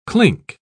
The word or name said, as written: clink